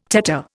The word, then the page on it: tetter: Indian